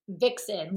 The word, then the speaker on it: Vicsen: Vixen, like Vixen. Victoria, and like Vixen being sexy, like okay. V-I-X-E-N. So this is Vic, V-I-C-S-E-N. Vixen. – Okay, you literally have a graphic explaining this, and I was still struggling. So thank you. – Yeah, no, it's Vixen, like how we've always heard, like what a Vixen is. So it's that, but it's spelled Vic, like ooh, Victoria.